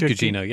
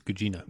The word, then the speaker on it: Gugino: It is Gugino, yes. – Gugino? I always get that name wrong. So. – Yeah. It's Italian, it's Gugino.